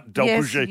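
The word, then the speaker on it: d'Alpuget: Is that, can I say that right? Delpuget. – Yes, you did.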